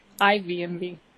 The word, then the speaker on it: IV&V: We have one from, looks like SLI Global Solutions, and then another one from, is that 1V&V Testing? How do you say that? IV&V Testing Solutions.